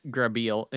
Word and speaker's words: Grabeel: Yeah, so Grabeel.